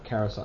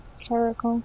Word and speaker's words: Caracal: Or Carousel. How do you pronounce that? – Caracal. – Caracal? Okay. It just sounded weird to me.